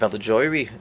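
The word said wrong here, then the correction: Bilgoraj: How do you pronounce it? – Bilgaray.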